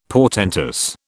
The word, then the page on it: portentous: British English